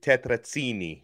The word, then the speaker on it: tetrazzini: Well, if you're going to really go the Italian pronunciation, tetrazzini. It's like a hard, the two Z's, it's like a hard Z.